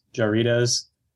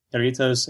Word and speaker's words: Jarritos: I went at great length over Jarritos, which Shelby got on me for pronouncing as Jarritos. Because mm-hmm. we're Midwestern, you see. That's how we pronounce everything. It's a J. But he's right. Jarritos and other goofy sodas.